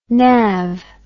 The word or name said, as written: nav